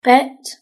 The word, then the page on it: pet: British English